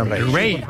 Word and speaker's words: Raye: Ray. Ray. Se escribe Raye.